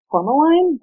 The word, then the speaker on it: Tourmaline: It also can be found in a gem called Tourmaline.